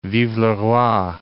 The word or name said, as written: vive le roi